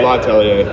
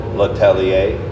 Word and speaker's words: L'Atelier: It's Atelier or L'Atelier. – I always try to pronounce that A. – L'Atelier. – Yeah, but there's an A there. – Yeah. – And I always want to pronounce the A. – Oh, yeah, yeah. – L'Atelier. Is that how you're saying? – Yeah.